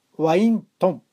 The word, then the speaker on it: ワイン豚: また一方、山梨ではワイントン、ワイン豚と書いてワイントンと読むらしいんですけど、こちらはですね、羨ましいことに毎日のようにワインを飲んでいるそうです。